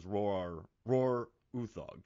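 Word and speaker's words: Roar Uthaug: So I read the Nordic pronunciation. It's like Roar Uthog, but, you know, he pronounces it as Roar Uthog.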